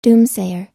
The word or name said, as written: doomsayer